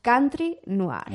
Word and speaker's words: country noir: a ver si lo digo bien, country noir. Muy bien.